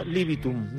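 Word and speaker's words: ad libitum: A ver si lo digo bien, Ad Libidum. – Eso, muy bien. – Libidum, pues mira, era Libitum, ¿no? – Ad Libidum.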